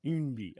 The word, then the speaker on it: Eunbi: Yeah, it's spelled E-U-N-B-I. – Umbi, okay.